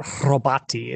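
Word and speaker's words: robota: And the word actually comes off of robati, or robati is, I guess, is the more proper Czech pronunciation.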